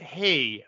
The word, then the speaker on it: hej: In high it's hey, but it's spelled H-E-J though, I think.